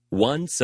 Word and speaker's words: once: 자, once라는 단어, once. 이렇게 들리지 않고요. once 하고 뒤에 있는 s 발음이 바람소리만 나되 조금 강한 바람소리입니다. 쌍시옷에 가까운 바람소리가 날 거예요.